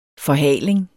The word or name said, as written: forhaling